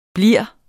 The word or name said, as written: blivende